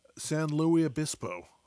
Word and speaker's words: San Luis Obispo: San Luis Obispo.